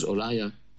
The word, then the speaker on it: Uriah: So the lady bathing was Bathsheba, the daughter of Eliam and the wife of Uriah. Some pronounce it as Uriah, the Hittite.